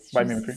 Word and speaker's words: Biomimicry: Oui, biomimicry. Ça se dit comme ça, oui.